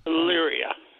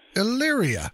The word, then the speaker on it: Elyria: I mean, I know it's Ohio, but how do you pronounce the name of the town? – Elyria. – Elyria. I probably yeah. would have butchered that.